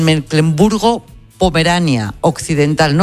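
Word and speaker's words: Mecklemburgo-Pomerania Occidental: Mecklemburgo Pomerania Occidental.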